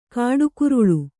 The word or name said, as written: ಕುಡುಗೊರಳು